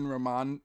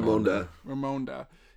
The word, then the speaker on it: Ramonda: I stopped myself from laughing in the theater, but I wanted to when he first introduces himself to Queen Ramon. How do you say it? I'm sorry. – Ramonda.